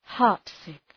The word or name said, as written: heartsick